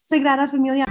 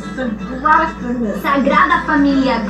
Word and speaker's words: Sagrada Familia: And everyone was like, are you going to the Sagrada Familia? – It's called the Sagrada Familia. Girl, you got an A in Spanish, but you pronounce it more than ours.